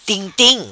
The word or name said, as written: Tim Tim